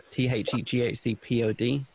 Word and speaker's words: THEGHCPOD: I said it wrong. I said THC. See what I mean, man? Don't do drugs. – It's THEGHCPOD.